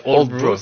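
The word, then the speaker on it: Aldeburgh: Oui, ça s'appelle Oldbro, ça s'écrit Aldebourg, mais ça se prononce Oldbro,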